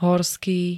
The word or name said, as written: horský